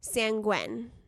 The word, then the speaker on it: sanguine: Sanguine. – Sanguine. – Sanguine. – We just did like the pronunciation and we already forgot. Wait, I think the emphasis was on the first syllable.